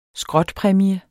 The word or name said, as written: skrotpræmie